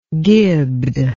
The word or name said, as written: gibbed